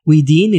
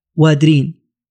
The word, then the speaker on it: ويدين: ويدين اللي ذكرناها آه، هي التي حرف نطقها عند العرب وأصبحت تنطق في شبه الجزيرة العربية بقلعة وادرين فمن